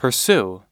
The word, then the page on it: pursue: American English